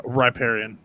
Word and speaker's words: riparian: or riparian. I don't know. I've only ever read it. I've never heard it pronounced. – Yeah, riparian. – Riparian.